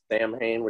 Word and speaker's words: Samhain: He pronounces it Samhain, which is, of course, how it's spelled, but I guess he's gotten lambasted by the Wicca community for mispronouncing their sacred holiday.